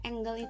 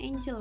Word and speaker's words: angle: Angle itu kalau tulisannya itu A-N-G-L-E, tapi bacanya bukan angel ya, tapi angle.